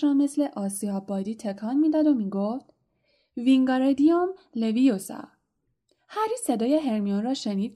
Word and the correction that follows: وینگاردیوم لویوسا: که با آواز تلخی می‌گفت: داری اشتباه تلفظ می‌کنی. باید بگی وینگاردیوم لویوسا. گارش را بهتر و کشیده‌تر تلفظ کن.